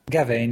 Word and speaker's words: Gawain: A történetre én olyan nagyon most nem ülnék föl, hogy kell kiejteni, Sir Gawain? Gawain, igen.